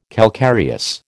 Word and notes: calcareous: American English

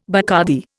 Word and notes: Bacardi: Indian

